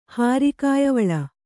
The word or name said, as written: ಹಾರಿಕಾಯವಳ